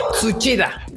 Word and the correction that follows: Tsuchiya: Tsuchiya, Tsuchiya. Ah, como Ana Suchilla.